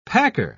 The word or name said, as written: packer